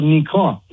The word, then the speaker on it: Nikon: It's actually called Nikon, the camera company. Nikon. That's how you pronounce it in Japanese.